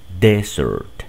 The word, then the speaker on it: desert: Acá en cambio, desierto, lleva solo una S y el acento recae en la primera sílaba. Desert, desert, desert.